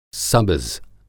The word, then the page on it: सबज़: سبز